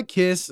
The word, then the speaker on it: Kiss: Kiss is also pronounced like quiche, the delicious baked custard dish, but you can make way more jokes by pronouncing it like kiss.